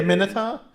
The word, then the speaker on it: Minotaur: Is it a Minotaur? – No. – It's a Majotaur. It's a Majotaur. – Minotaur. I said it wrong. My bad.